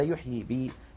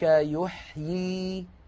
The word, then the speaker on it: يحيي: لا بد من رد الياء المحذوفة فينطق هكذا كذلك يحيي. قولوا لو سمحتم كذلك يحيي. – أيوة، لا يقول كذلك يحي على وزن يفع،